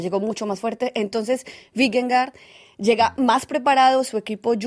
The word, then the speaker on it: Vingegaard: Atención, porque esto me lo han enseñado a pronunciar. Vingegaard, aunque lo leamos escrito Vingegaard, para que nadie se confunda.